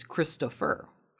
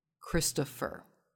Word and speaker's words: Christopher: My birth name is Christopher. And in Christopher, the way it's spelled with me, there's a PH, where you might think there'd be an F. Christopher.